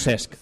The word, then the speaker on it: Cesc: Es como cuando dicen Cesc Fàbregas, el pobre Cesc. Lo pronuncian de mil maneras menos Cesc. – Cesc.